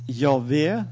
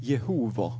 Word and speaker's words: Jahve: Men vi i den vetenskapliga traditionen, så ser vi, har vi bestämt oss så att säga för att Jahve sannsynligvis var nog i rättning av slik det blev uttalt en gång i tiden. Och därför brukar vi det. Läser där lite äldre litteratur, faktiskt inte mer än 100 år gammal eller knappt det, så brukte forskarna gärna Jehova. Och det blev nog heller aldrig uttalt slik, men man brukte det i mangel av något bättre, kan man säga.